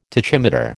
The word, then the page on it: tachymeter: American English